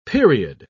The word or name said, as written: period